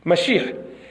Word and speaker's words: Mashiach: They say the actual root of this word, the actual pronunciation of this word is Mashiach.